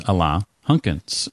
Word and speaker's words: Alain Hunkins: And his name is Alain Hunkins.